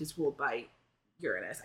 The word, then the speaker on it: Uranus: Uranus is the correct pronunciation.